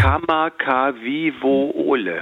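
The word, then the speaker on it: Kamakawiwole (Kamakawiwoʻole): Kamakawiwiwowe? – Ole? Würdest du mich jetzt so... Ich hab's jetzt gerade auch lustigerweise mir ja nochmal vorgeholt. Ja. Kamakawiwole.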